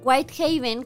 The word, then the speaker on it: Whitehaven: Whitehaven, que se pronuncia así, es una historia súper linda porque era un matrimonio retirado.